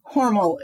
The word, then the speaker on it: Hormel: Hormel is how the family pronounces it.